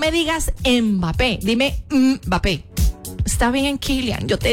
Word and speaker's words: Mbappé: Mbappé, a ver, no se dice Mbappé, sino que se dice Mbappé.